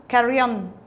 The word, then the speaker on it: Carrion: How do I say your surname? – Carrion. – Okay. – Spanish name.